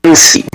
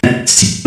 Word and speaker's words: Annecy: Denos un contexto, por favor, a propósito, la importancia de este galardón y cómo se dice Annecy. – Annecy. – Annecy. – Obviamente, eso lo pronuncio, es algo así.